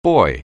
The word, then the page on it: boy: anglais